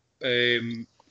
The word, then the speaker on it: Iheanacho: You know, proper plans, not Perez, not Iheanacho. Yeah, that's how you pronounce it.